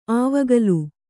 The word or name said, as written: ಅವಿಕಲ